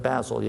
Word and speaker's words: Basel: But he led the Reformation in Basel. Is that Basel? Yeah.